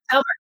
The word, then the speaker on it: Talbert: Talbert.